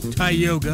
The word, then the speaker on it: Tioga: Tioga, as we pronounce it today, is a variation of the Iroquois word Dioga, which roughly translates to at the forks.